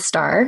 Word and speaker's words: Starr: My name is Krystle Starr, and Starr is spelled with two R's.